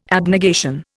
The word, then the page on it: abnegation: Indian